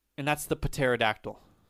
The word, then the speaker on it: pterodactyl: The pterodactyl, it's got the silent P.